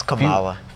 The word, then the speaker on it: Kamala: It's Kamala. – Future... Vice President of the United States. – Oh, you know, it's either Kamala or Kamala.